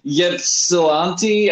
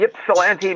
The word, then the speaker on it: Ypsilanti: It's Ypsilanti. Who spells anything with a YPS as the first three letters of a word? – You've never heard of Ypsilanti, Michigan? – Ypsilanti, Michigan.